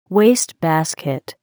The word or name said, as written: wastebasket